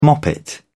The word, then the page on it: moppet: British English